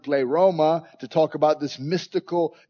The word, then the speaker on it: pleroma: The word translated fullness is the Greek word pleroma, P-L-E-R-O-M-A. It's a word you want to know, pleroma. It's how you say it. P-L-E-R-O-M-A is the Greek word.